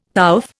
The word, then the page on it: pouffe: Indian